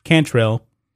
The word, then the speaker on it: Cantrell: And they're run by uh, Latoya Tranquil. I believe that's the way you pronounce her uh, her name, Cantrell. That's the way you pronounce her name.